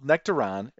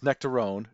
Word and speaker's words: Nectaron: In 2022, many sources labeled Nectaron as the next big thing in hops. Though you might hear me mispronounce Nectaron as Nectarone due to my Midwest accent, it might seem to take its name from the nectarine.